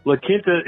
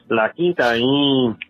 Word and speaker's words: La Quinta: La Quinta is Spanish for the Quinta, and it's pronounced La Quinta.